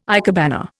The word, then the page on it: ikebana: Indian